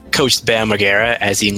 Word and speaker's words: Margera: Bam Margera? – Margera. – Margera.